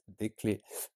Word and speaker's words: des clés: Des clés, silent S, des clés.